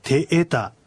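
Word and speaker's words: テエタ: あとアイヌ語では昔はこうえーとかいーとかって伸ばすのではなしに、音を伸ばすときには、例えば今日の勉強にあるようにてえたとあります。これもはっきりとええと二つの母音を区切って発音するということです。そしてアクセントは二つ目のえにあるてえた、てえたじゃなくててえたとなるわけです。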